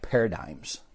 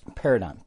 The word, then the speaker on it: paradigm: So here we go. Paradigm. P A R A D I. This is will fuck you over. There's a G in it and an M.